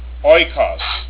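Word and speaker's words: oikos (οἶκος): Next diphthong is omicron, iota, pronounced oi, as in oikos, the word for house, or the form for house in the New Testament.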